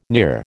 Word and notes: near: American English

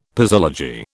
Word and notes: posology: British English

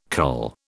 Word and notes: cull: British English

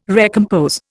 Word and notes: recompose: Indian

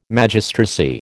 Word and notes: Magistracy: American English

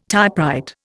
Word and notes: typewrite: Indian

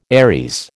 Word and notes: Aries: American English

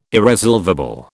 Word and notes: irresolvable: British English

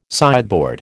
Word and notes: sideboard: American English

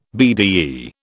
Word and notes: Bde: British English

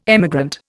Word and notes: emigrant: Indian

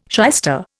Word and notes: shyster: Indian

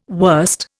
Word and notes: wurst: Indian

